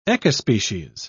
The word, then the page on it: ecospecies: British English